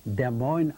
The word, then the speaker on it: Des Moines: And he said to me, this is Des Moines, Iowa. He said, here you got an S, you don't pronounce. Here you got an N. And Iowa is, the I there is a yo.